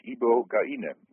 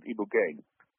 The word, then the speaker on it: ibogaine: We call it in Dutch ibogaine, but you can also pronounce it ibogaine.